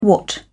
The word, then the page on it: watt: British English